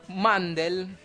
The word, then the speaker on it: Mandel: El show fue creado por el actor y comediante Howie Mandel, como Mandela pero sin la A, quien también dio las voces tanto a Bobby como a su padre, que también se llamaba Howie.